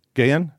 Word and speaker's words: Gahan: How do you pronounce his first name? – Gahan. – Gahan? Okay.